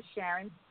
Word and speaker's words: Charon: So I made this dope, I mean, everything that I, if you've never seen my swimsuit, go to Gwan by Charon, G-W-A-N by B-Y, Charon. And my name is spelled with a C, people, C-H-A-R-O-N.